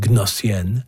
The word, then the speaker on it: Gnossienne: Here's one of his created genres, the Gnossienne. That's what the silent G, in case you're wondering, Gnossienne, number one, played by the Montreal pianist Bruce Liu.